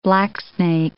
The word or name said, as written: blacksnake